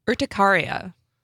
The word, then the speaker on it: urticaria: Urticaria.